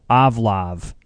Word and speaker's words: Ovlov: This band is called Avlov. It's spelled O-V-L-O-V. It just happens to be Volvo spelled backwards.